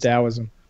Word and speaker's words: Taoism: It's spelled Taoism, but it's pronounced Taoism.